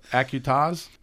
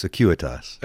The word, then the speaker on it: Acuitas: Did I pronounce it right this time? – Close. Acuitas. – Acuitas. – It's Latin, so don't beat yourself up. – Acuitas. I know what I did wrong. See, I split it up and I didn't put a Q in it.